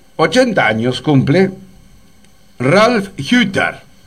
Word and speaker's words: Hütter: Se escribe Uter con la U. Con la U con diéresis, sería una I. – Uh-huh. – Así que sería Hütter. Hütter, porque es con H además, ¿no?